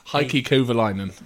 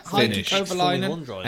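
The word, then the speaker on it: Heikki Kovalainen: Heike Kovalainen. – Him? – Yes. – Yeah. Heike Kovalainen.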